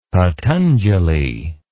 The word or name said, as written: patanjali